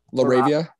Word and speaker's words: LaRavia: One of the kids in your guys' grade, his brother, Jake how do you pronounce it? – Laravia.